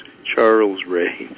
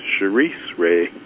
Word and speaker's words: Charisse Ray: And I was just informed that I was mispronouncing the name of the band that's going to be performing this Wednesday. It looked to me like it was Charles Ray. It's apparently pronounced Charisse Ray.